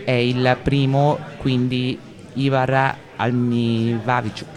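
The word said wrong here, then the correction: Ihar Alinevich: Can you help me with the right pronunciation? Sto chiedendo a Vladimir se mi aiuta con la giusta pronuncia, perché... – Eh sì, Igor Alinevich.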